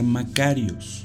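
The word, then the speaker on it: macarios (μακάριος): Y del griego se pronuncia macarios.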